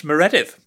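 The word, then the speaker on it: Meredydd: My name is pronounced Meredith and I'm very pleased to be here.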